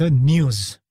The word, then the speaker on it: N3W5: यो ब्रान्डको नयाँ के अरे नाम रहेछ न्युज भनेर उच्चारण गरिने रहेछ। N3W5 भनेको NEWS टाइपले चाहिँ राखिएको रहेछ, र प्रोनाउन्स गर्दाखेरि, उच्चारण गर्दा चाहिँ न्युज भनिँदो रहेछ, तर N3W5 यसो हेर्दाखेरि त्यस्तो छ तर न्युज भन्न पनि मिल्ने रहेछ।